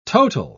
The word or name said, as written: total